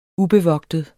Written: ubevogtet

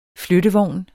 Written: flyttevogn

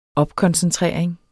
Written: opkoncentrering

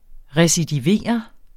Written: recidiverende

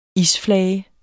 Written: Isflager

